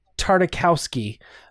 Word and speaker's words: Tartakovsky: Tartakovsky.